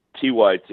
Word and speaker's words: TYT: Why don't you tell people what TIT is and why it is that liberals can't seem to do any like hard news without it being sort of sexual? – Well, you're mispronouncing it, Ken. It's TYT.